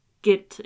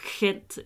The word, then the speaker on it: Gent: Kops flok var nu også sikre på, at meddelelserne stammede fra Belgien et sted, øh, sådan et sted ved kysten mellem Brygge og, øh, og Gent, eller Gent, eller Gent, alt efter hvilket sprog man ligesom har lyst til at udtale det på.